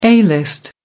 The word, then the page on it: a-list: American English